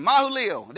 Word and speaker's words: Mahalalel: Verse number 17, Mahalalel, that's how you say it.